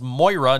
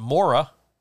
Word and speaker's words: Moira: It was enough for me to be like, okay, I know that this character's name is Moira, not Mora.